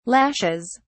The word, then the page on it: lashes: British English